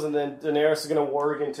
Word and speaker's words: warg: and then he's going to somehow warg. Am I saying that right? – Warg, yeah.